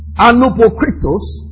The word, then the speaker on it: anupokritos: It is the word, I'll have to spell it for you. Anupokritos. A-N-U. Now when you have eight, alpha, that's negative. A-N-U-P-O-K-R-I-T-O-S. A-N-U-P-O-K-R-I-T-O-S.